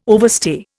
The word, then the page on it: overstay: Indian